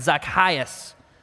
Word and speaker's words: Zacchaeus: Zacchaeus is actually pronounced like a karate chop kind of sound, Zacchaeus.